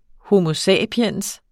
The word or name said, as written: homo